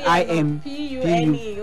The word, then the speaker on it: impugn: And then I M P U G N. Impune. Jenu is silent. You don't pronounce it.